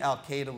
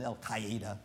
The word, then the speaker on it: Al-Qaeda: The FBI interrogated many Al-Qaeda lieutenants, Al-Qaeda lieutenants. I still use the bad pronunciation. Al-Qaeda is right.